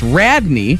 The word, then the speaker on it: Rodney: Taking on Rodney this morning, pronounced locally as Radney.